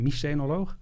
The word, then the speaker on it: mycenoloog: Zeg ik het goed als archeoloog en mycenoloog? – Dat is wel correct, ja.